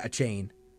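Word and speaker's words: Achane: 4 touchdowns for, how do you say, Achain? Achain? – Yeah, Achain. – Achain. – Devin Achain.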